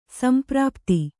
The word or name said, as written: ಸಂಪ್ರಾಪ್ತಿ